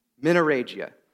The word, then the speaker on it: menorrhagia: I had to like go, it's a long word, and I'm like, there's no way it's even pronounced that way at all. But it is. Menorrhagia.